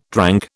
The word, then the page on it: drank: British English